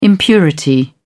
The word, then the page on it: impurity: British English